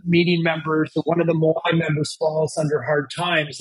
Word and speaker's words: Moai: Well, riffing off of that, in Okinawa, they have this beautiful practice of the Moai. It's spelled M-O-A-I.